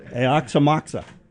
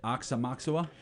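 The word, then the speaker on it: Aoxomoxoa: Donnie Kiffmeyer taught me the correct what he says is the correct pronunciation. – How do you say? – I gotta find it. Where did it go? Aoxamoxa.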